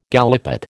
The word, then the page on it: gallipot: American English